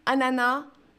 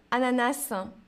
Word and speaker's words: ananas: Alors, qu'est-ce que, qu'est-ce que j'ai décrit ici Alors, Daniela, on ne prononce pas le S pour ananas. Les deux, ananas, ananas. Je pense qu'on peut dire les deux.